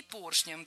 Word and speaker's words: поршням: Клапанам и поршням. Оба слова произнесены неправильно. Ошибка однотипная. Должно быть клапаном и поршнем, так как перед нами слова с неподвижным ударением на основе. И в них ударение всегда падает на первый слог.